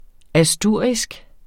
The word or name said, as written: ASTURISKE